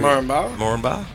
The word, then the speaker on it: Moranbah: Moranbah.